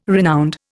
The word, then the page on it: renowned: Indian